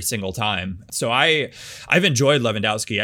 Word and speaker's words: Lewandowski: It's Lewandowski, boys.